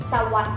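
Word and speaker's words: sawaddi (สวัสดี): xin chào tiếng thái đọc là đi sawaddi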